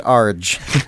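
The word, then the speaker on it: ARG: An Arge. An Arge. – I pronounce it Arge.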